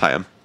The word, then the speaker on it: Haim: No, that's how they how you pronounce it. – I thought it was Haim. – No, it's Haim. – Are you sure? – Yep. – Okay. – And I watched them do a video where they said their name. – Okay. – They pronounced it Haim.